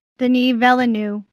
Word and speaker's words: Denis Villeneuve: Dune, the new Denis Villeneuve, did I pronounce that right? – Denis Villeneuve. – What she said, it has been delayed till 2021.